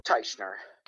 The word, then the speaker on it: Teichner: Teichner.